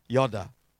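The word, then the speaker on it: Yoda (ידע): It's the Hebrew word, and it's pronounced more like Yoda, like Star Wars, Yoda, Yoda.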